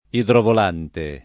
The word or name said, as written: idrovolante